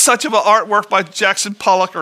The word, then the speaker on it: Jackson Pollock: Am I saying that right, Tom? Jackson Pollock? Okay.